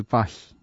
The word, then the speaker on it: Paris: 여기에 주제곡이기도 했던 술루 시엘 드 파히. 네, 어, 파히는 발음 잘한 것 같아요.